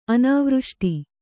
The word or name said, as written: ಅನಾವೃಷ್ಟಿ